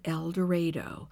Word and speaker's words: El Dorado: Yes, they pronounce it El Dorado. I called their city hall to check.